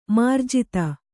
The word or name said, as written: ಮಾರ್ಜಿತ